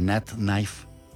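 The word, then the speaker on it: Nathnife: Y búsquenla como Nat Knife ahí en todas las plataformas de música. Ella está como N-A-T-H-N-I-F-E, o sea, Nat Knife.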